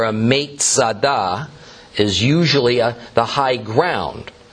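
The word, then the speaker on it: Metzada (מצדה): Masada is merely a Roman way to pronounce the Hebrew word Metzada, which means fortress.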